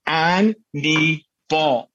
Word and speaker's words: Hannibal: And he goes, it's Anne, like the girl's name. And he pointed to his knee, and ball like a baseball. He goes, repeat after me, Anne, knee, ball.